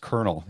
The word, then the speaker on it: Colonel: Yeah, it's pronounced Lieutenant Colonel. It's funny. It's one of those words. I don't know why it's spelled the way it is, but it's pronounced Colonel. Yeah, like K-E-R-N-E-L. It's funny. Yeah, a lot of people will say Colonel because that's how it's spelled.